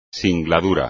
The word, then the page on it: singladura: espagnol